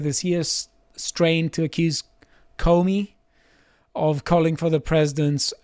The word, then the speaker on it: Comey: I think it's pronounced Comey, but Comey. I like your way of saying it as Comey.